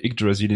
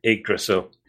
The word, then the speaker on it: Yggdrasil: As a Scandinavian, I can say that your pronunciation of Yggdrasil is entirely accurate, and if anyone doubts it, doubts it, they can hear Hugo Weaving pronounce it in much the same way in Captain America: The First Avenger, which by coincidence I saw only a few days later.